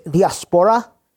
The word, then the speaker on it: diaspora: And so the Greek word for scattered there is diaspora, and I actually pronounced that correctly.